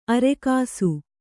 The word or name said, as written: ಆರೊಗಿಸು